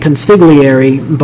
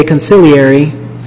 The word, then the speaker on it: consigliere: Honestly, the whole time I was reading the novel and watching the movie, like reading the novel, I was pronouncing it consigliere, but then in the movie they say consigliere, so I realized that I now realize that the G is silent.